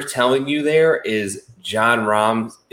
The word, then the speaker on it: Rahm: At the tippity tip top, you have John Rahm. 5.5-1. Joe, can you argue that this is a good bet? – Roll that R on John Rahm.